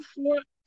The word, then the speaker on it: vor: Um, vor, vor uh, and nach.